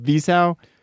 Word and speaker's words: Wiseau: Wiseau.